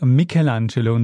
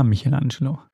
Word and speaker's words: Michelangelo: Michelangelo. Ich immer Michelangelo. – Ja, ich meine, Michelangelo ist halt die deutsche Aussprache.